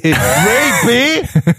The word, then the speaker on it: Ray-Ban: Enfim, eu quero aí um óculos dessa marca aí, que o Hawk odeia a forma como eu pronunciei. – É, porque... Ray-Ban! – É o nome certo, o que eu posso falar? – Vai tomar no cu, rapaz.